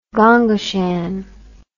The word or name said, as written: gonggashan